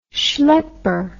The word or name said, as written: schlepper